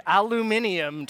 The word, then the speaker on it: aluminum: Aluminum. Okay, aluminium to our British friends.